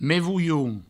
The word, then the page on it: me volhon: me vouioun